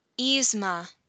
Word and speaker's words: Yzma: Kronk is, how do you say her name? Y-Z-M-A. – Yzma.